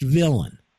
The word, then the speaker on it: villein: Now, it's French because it came over from Europe, and it's spelled V-I-L-L-E-I-N, right. Villain, but it's pronounced villain, okay?